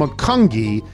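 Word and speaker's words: Macungie: The correct pronunciation from McCungi is McCungi, as McCungi. McCungi, not McCungi.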